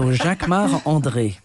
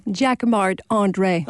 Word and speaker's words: Jacquemart-André: Jacquemart-André. Jacquemart, or no, you say it like André. A Frenchman. Jacquemart, of course, because I'm French. So Jacquemart-André.